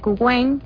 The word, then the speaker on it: Qwen: Como hablamos de las inteligencias artificiales, tenemos Gemini, que es gratis, Gemini, DeepSeek, Kuwen, que se escribe Qwen, y DeepSeek es como una ballenita.